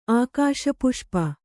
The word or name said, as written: ಆಕಾಶಪುಷ್ಪ